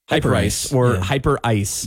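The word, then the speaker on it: Hyperice: They're teaming up with, I guess it's called Hyper Rise is how it's pronounced. – Yeah. Uh-huh. – It's spelled Hyper Rise. Hyper Ice. – Yeah. Mm-hmm. – So it makes no sense.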